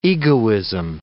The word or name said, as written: egoism